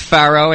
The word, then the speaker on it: Faro: F-A-R-O. Not as in the ancient pharaohs, but Faro.